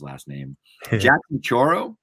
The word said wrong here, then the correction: Chorillo: correct? – Jackson Chorillo is what I would say. – Say it again. – Jackson Chorillo.